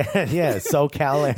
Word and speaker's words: SoCal: Yeah, SoCal. – SoCal Andrew, that's how you say it.